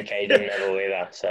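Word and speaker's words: Cadeyrn: It's Caden Neville, mate. It's pretty simple. Caden. 2 syllables.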